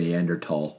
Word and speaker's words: Neanderthal: Every picture of a Neanderthal, or, which I always pronounced Neanderthal, but found out over the last long period. – If you're educated and snooty, you say Neanderthal. – You do, because the H is silent.